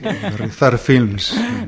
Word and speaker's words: Berriozar Films: Sí, sí, Berriozar Films, Berriozar, para los amigos.